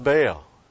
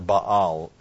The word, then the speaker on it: Baal: It set the people up for religious syncretism of true worship of God with the fertility cult of Baal, or Baal, actually, the Hebrew way of pronouncing that, but anyway. But most of us just say, that's Baal.